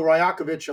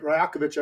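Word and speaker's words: Rajaković: And they also, by the way, point out, you know, new coach, you know, so Darko Rajakovic, I hope I said it right, Rajakovic, I believe is how you pronounce it.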